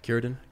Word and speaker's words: Círdan: Círdan.